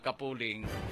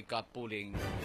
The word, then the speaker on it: Kapuling: Kaya ginaaral kita may mga koreksyon lang sila sa mga tumandok sa barangay kapuling. Mapronounce sini gali sa sirila barangay sini. Kapuling, not kapuling.